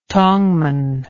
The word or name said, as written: tongman